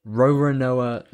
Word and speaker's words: Roronoa: Roanoa? How do you say his first name? – Roronoa Zoro.